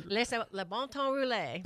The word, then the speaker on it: Le bon temps roule: Well, how do I say roll it? How do you say it? – Le bon temps roule. – There you go.